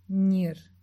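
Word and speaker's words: gĩr: Outro exemplo é a palavra para designar criança, nir, que se escreve G-I-R com acento til na letra I.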